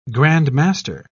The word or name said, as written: grandmaster